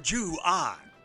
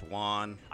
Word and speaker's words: Juan: Greetings, Sir Jew Ahn. – It's pronounced Juan.